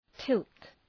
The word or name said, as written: tilt